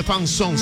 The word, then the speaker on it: Fanzone: Fan Z-O-N-E. Fanzone, se escribe así. Se pronuncia Fanzón.